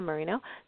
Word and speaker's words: Marinho: So, and the last name for everyone is spelled MARINHO. So there's an H in there. So Anna Marino.